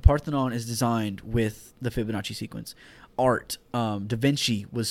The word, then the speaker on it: Fibonacci: Am I saying it right? – Fibonacci.